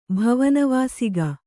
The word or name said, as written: ಭವನವಾಸಿಕ